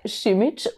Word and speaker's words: Simic: So, liebe Julia Simic.